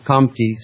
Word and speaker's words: Comte: Auguste Comte and the positivist school, that's spelled C-O-M-T-E, I pronounce it Comte so that you'll hear it properly phonetically and you can go and type it into your browser, but it was Comte. Kant, you just bury the N-T at the end of it, Kant, like Count, like Immanuel Kant, easily on the radio misunderstood.